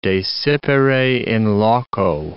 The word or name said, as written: desipere in loco